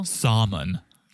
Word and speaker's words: salmon: Salmon. Did you hear how he said it? Oh, Katina loves seafood. She loves salmon. – Well, he was making sure that he doesn't pronounce the L.